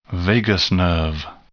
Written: vagus nerve

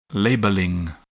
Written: labeling

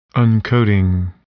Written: uncoating

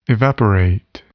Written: evaporate

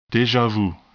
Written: deja vu

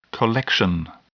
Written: collection

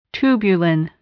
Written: tubulin

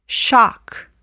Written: shock

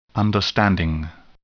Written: understanding

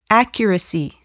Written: accuracy

